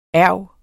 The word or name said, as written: erg